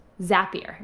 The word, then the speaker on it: Zapier: I always say that wrong. Zapier. Rhymes with happier.